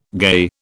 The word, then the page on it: gay: British English